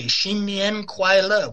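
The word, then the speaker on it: Xin Nian Kuai Le (新年快乐): You say Xin Nian Kuai Le, which just literally means New Year come quickly and bring good luck.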